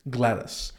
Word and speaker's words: GLaDOS: GLaDOS.